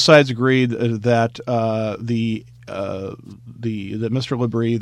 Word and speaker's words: Labrie: And this is the case of Owen LaBrie, I believe is how this young man's name is pronounced.